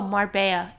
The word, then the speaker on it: marbella: Oh, marbella. It's so, but it's spelt marbella, mm.